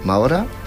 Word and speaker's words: Maura: Pour le 15, nous souhaiterons l'anniversaire de Carmen Mora. Alors, je ne dois pas bien le prononcer, puisque c'est une actrice espagnole. Carmen Maura, qui va fêter donc ses 72 ans.